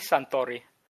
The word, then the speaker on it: Suntory: Would one of you want to say how it's actually pronounced? It's Hibiki Suntory.